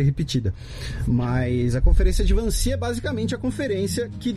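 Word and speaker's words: Wannsee: 20 de janeiro de 1942, há 75 anos, ocorria a Conferência de Van Ness. – E, não, na verdade é a conferência de Vancy, meu caro Matias. Ah. Seu alemão tá em. Você falou em holandês, é alemão.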